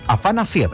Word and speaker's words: Afanasiev: ¿Lo dije bien? Afanasiev, eso es.